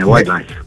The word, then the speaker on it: White Lies: Sì, è detto bene, White Lies.